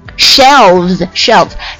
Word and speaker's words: shelves: 好，下面这个书架架子 shelf，它的复数就是 shel，把 F 变成 VES，读作 shelves。